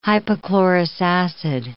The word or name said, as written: hypochlorous acid